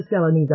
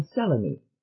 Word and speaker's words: Selene: I am Selene, all three syllables. Selene," she said, coming down heavily on the first syllable, "to those who use my first name at all."